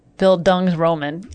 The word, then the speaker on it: Bildungsroman: Bildungsroman.